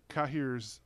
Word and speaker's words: Cahir: And I also like, you actually, Cahir's, which rhymes with Emir, which is a problem throughout this, right? – Mm.